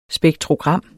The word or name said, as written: SPEKTROGRAM